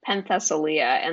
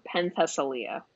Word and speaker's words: Penthesilea: Penthesilea, and the only reason I know how to say that is because I listened to the audiobook. So it was repeated over and over. So Penthesilea. – Penthesilea, Penthesilea, Penthesilea.